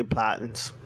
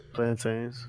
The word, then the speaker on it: plantains: I know Nigerians say platins. – So. How do you say it? – Plantains. – Okay, that's how I say it too. Alright, I thought Nigerians say platins. – No, they say plantains.